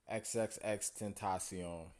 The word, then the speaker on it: XXXTENTACION: XXXTENTACION.